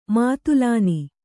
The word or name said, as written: ಮೊದಲನೆ